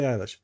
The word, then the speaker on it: Eilish: Is it Eilish or Eilish or Ellie or? – Billie Eilish.